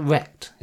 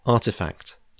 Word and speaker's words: RTFKT: And given Nike's position in all of this, acquiring RTFKT, do you pronounce it Rekt? Is that how I meant to say it? Maybe not. – Sorry. Yeah, Artifact. – Artifact.